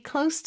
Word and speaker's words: close: close, Z 발음으로 하면은 닫다 라는 뜻이 되죠. 그래서 보통은 뭐 예를 들어서 close the door, close your eyes 이렇게 뭐 눈을 감아요, 문을 닫으세요 이렇게 동사로 쓸 때는 Z 발음을 씁니다. 하지만 여기서는 가까운이라는 뜻이기 때문에 S 발음으로 꼭 해주셔야 합니다.